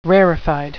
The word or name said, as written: rarefied